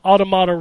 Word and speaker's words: automata: Y'all are pronouncing uh, automata wrong. It's the plural form of automaton, and the first three syllables are pronounced the same.